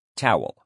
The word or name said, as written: towel